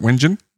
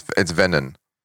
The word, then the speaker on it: Wengen: Is it Wengen in Switzerland? – That's pronunciation of... – It's probably Venyen. – It's Venyen.